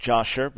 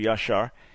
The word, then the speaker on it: Yashar: What is your opinion on the book of Yashar, normally pronounced Yashar, but in Hebrew Yashar, and its reliability?